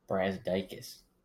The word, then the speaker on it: Brazdeikis: Ignis Brazdeikis. I probably just butchered his name. He's from Ontario. – Brazdeikis.